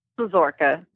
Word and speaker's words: Szesciorka: Szesciorka.